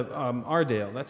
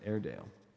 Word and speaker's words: Aredale: it's not your Dale, it's not my Dale, it's Ardale, because the town is spelled A-R-E-D-A-L-E, and so it's kind of a pronunciation guide because so many people pronounce it Airedale. – Airedale. – But it's Ardale. – Okay, okay. Anyway. Not like the dog.